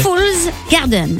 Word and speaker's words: Fool's Garden: Fool's Garden.